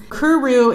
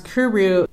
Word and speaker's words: KVRV: So this is Kuru. It's all caps KVRV, but it's pronounced Kuru. And I'm playing the track for you, Gender Fuck, on their album Swank and Strut, and it's an independent band, Kuru, and again, that's KVRV.